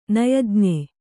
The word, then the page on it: ನಯಜ್ಞ: nayajña